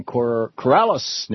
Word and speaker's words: Corrales: And Barbara in Corrales, New Mexico. Am I pronouncing that right, Barbara? – Yes, you're doing fine.